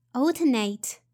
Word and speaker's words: alternate: Number four, alternate alternate. Notice the stress is in the first syllable, al, so alternate, alternate.